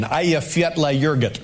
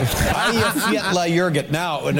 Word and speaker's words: Eyjafjallajökull: Because the name of the volcano is so difficult to pronounce, it's pronounced, here's the pronunciation, Eyjafjallajökull.